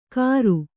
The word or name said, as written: ಕರು